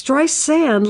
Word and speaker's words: Streisand: It's Streisand, like sand on the beach. Now, how simple can you get? Sand on the beach. Streisand.